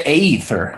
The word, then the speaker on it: aether: Well, right, it's better than the ether, except you know, or the aether. – Well, that's the A is silent.